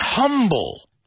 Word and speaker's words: humble: M-U-M-B-L-E. They all rhyme. Crumble, bumble, rumble, mumble. The next one is humble. Humble, a very, very important quality. Humble, your humility. Humble. Sound familiar in Spanish? Humildad. The H we pronounce, right? We say ha, humble.